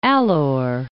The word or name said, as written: Alor